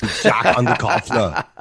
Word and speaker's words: Underkoffler: Did I pronounce it right? – Yes, you did. Yeah. You nailed it. – All right. – Underkoffler, that's right. You know, honored to say it much better than you, Danny. Jack Underkoffler.